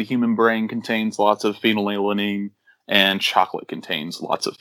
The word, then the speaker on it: phenylalanine: phenylalanine, I think is the way you pronounce the chemical name, phenylalanine.